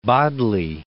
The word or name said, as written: Bodley